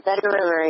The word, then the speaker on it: Vedder: Grant in Surrey, BC, he says, I fish your Vader or Vetter? – The Vetter River, yes. – And never, how do you spell that? – The Vetter? – V-E-D-D-E-R? – V-E-D-D-E-R, yeah. – Oh, okay.